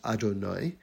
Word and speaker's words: Adonai: And then another name spelled Aleph, Dalet, Nun, and Yud, pronounced Adonai.